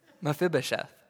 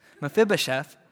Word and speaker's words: Mephibosheth: Did I say Methuselah? Oh man, I'm sorry. Mephibosheth. Thank you. You guys are the best. Mephibosheth, the son of Jonathan, the son of Saul, was come unto David.